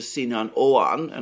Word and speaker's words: Sinan Oğan: Now, I just want to just mention here that it seems that the third candidate, Mr. Sinan Oğan,